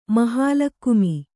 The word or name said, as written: ಮಹಾಲಕ್ಕುಮಿ